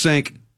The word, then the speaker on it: Cenk: One of the hosts there, Cenk Uygur, am I saying that right? I know it's Cenk. – I think so. – Cenk, C-E-N-K, Uygur, U-Y-G-U-R.